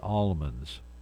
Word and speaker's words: almonds: Is it almonds or almonds? I like to pronounce the L, almonds.